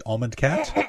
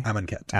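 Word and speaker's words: Amun-Ket: If you're bad at saying it, it's the Almond Cat. – Ket. So how do you say it properly? – Amon Ket. – Amon Ket.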